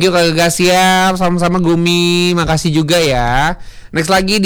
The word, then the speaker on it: Goomi: Atau G-O-O-M-I, Gumi. Gumi, dibacanya Gumi.